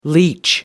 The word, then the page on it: leach: American English